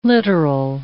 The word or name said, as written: literal